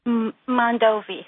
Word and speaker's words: Mondovi: Am I saying that right, in Wisconsin? – Mondovi. – Mondovi.